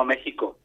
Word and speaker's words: México: La X, hay una cuestión ahí de... de pronunciación, sí. Pero yo veo que aquí también se utiliza la J, pero vamos, lo correcto siempre ha sido México desde hace mucho tiempo, ¿no? – Vale, México y Texas, de acuerdo, vale.